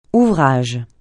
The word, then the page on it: ouvrage: francais